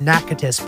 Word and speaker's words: Natchitoches: I finally arrived in the Natchitoches Parish, which is spelled nothing how it's pronounced. It's spelled N-A-T-I-T-O-C-H-E-S. Which I don't know how that's Natchitoches, but apparently it is.